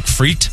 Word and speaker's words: frites: Did you know the French don't even pronounce the S? They just say steak frites? – Yeah.